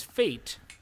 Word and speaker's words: Fait: And also, his name is Fate, but it's spelled F-A-I-T, according to the credits. – F-A-I-T. Oh, God. – Yeah. – What a choice. – Which is Faith without the H.